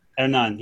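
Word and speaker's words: Hernán: Let's properly pronounce it with the Spanish. Hernan, yes.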